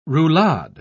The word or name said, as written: roulade